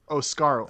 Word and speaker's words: Oscuro: Walks out, he does his shit, and then we get the debut of Pinta Oscaro. Is that how you say it? – I don't I know how to no say clue. it. – Oscuro. – Uh, o S C U R O.